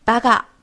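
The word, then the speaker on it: ばか: 先记住“ 八嘎” 是由“ 八咔” 两个假名构成的，然后再练习读音：“ 八嘎八嘎八嘎”。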